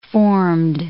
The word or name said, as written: formed